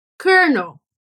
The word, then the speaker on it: colonel: Como que escreve essa palavra? C-O-L-O-N-E-L. E pronuncia colonel.